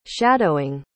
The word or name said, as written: shadowing